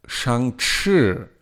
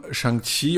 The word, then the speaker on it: Shang-Chi: Und das wird so phonetisch geschrieben für die Amerikaner oder Europäer Chi. Und deshalb ist Shang-Chi, spricht man so aus in Amerika oder sowas. Und in China sagt man Shang-Chi, aber wenn man da schreibt Shang-Qi, weiß man nicht, wie man das ausspricht.